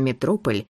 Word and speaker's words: Метрополь: А жаль, когда еще придется оказаться в таком месте, как ресторан «Метрополь», с ударением на втором слуге, в отеле «Эксельсиор».